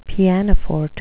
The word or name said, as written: pianoforte